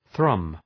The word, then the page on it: thrum: ɵrʌm